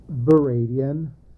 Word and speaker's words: Varadian: Yes, my name is Jake Varadian. Last name, capital V as in Victor, A-R-A-D-I-A-N.